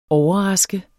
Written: overraske

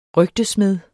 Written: RYGTESMEDS

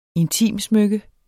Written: intimsmykke

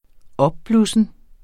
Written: opblussen

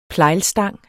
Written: plejlstang

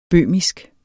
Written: bøhmisk

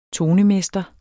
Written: tonemester

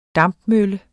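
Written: dampmølle

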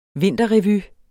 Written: vinterrevy

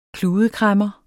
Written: kludekræmmer

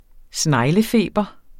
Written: sneglefeber

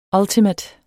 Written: ultimate

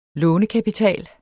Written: lånekapital

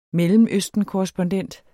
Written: mellemøstenkorrespondent

